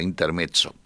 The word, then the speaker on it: Intermezzo: Intermezzo con doble, yo lo pronuncio a la italiana con doble Z, Intermezzo.